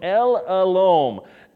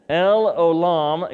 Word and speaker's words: El Olam: Number 9, if you're taking notes, El Olam. El Olam, excuse me, I think I pronounced it wrong the first time.